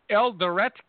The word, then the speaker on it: Eldoret: Now, if I'm mispronouncing this, please help me out. Is it Eldoret, Kenya? – Yeah.